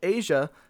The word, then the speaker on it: Aja: Well, Aja, thank you so much for coming on the podcast.